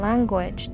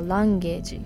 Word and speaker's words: language: で、 When it comes to learning a language っていうのは Learning a language この発音もポイントなんですけど Language っていうのは Language 言語っていう意味です。カタカナでは Language って言うと思うんですけど英語の発音は Language Language です。